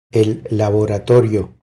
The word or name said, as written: El laboratorio